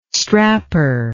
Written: strapper

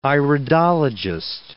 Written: iridologist

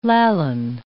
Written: Lallan

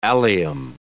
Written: allium

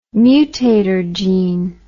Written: mutator gene

